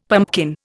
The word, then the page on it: pumpkin: Indian